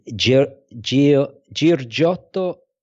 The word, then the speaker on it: Giorgio Giugiaro: Giorgio Giugiaro.